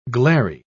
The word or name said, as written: glary